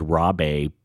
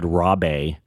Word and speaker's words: Rabe: And actually, Mike Nichols and David Rabe, I had to look up how to pronounce David Rabe. I thought it might maybe pronounce David Rabe, and I just found out that I've been actually saying Broccoli Rabe wrong my entire life, but I will continue to say Broccoli Rabe.